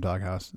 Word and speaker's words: Dog Haus: Sp- it's spelled uh, like a German house, like H A U S. There you go. That's that's how it's supposed to be.